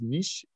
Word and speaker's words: niche: Well, I think the first thing to clarify, I know niche rhymes with rich, but it's niche. It's niche. I'm sorry. – Niche to be niche. There, it's a French word. It's pronounced niche. So it's niche. But I know that doesn't rhyme with rich.